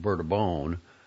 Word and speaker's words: Bourbon: Battelle's farms are dominated by a temperamental but fertile cultivar called Bourbon, pronounced Bourbon, a member of the Arabica species named for the Indian Ocean island of Réunion, formerly known as Bourbon, where it thrived.